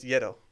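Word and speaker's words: gyro: How do you actually pronounce that word, though? – Euro. – It's Euro. – Giada says Yedo.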